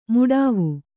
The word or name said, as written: ಮೊಡವೆ